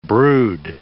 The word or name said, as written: brood